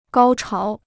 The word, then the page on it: 高潮: gāo cháo